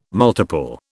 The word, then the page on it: multiple: British English